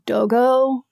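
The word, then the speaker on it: Dogo Argentino: It's probably pronounced like dogo.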